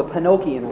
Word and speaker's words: Penokean: Pinocchian is P-E-N-O-K-E-A-N. It has nothing to do with Pinocchio. but it is pronounced the same way, and the name always startles people when they first hear it.